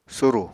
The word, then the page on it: surù: apatani